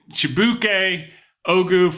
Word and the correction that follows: Chibike Ogu: Okay, the correct pronunciation is Chibike Ogu.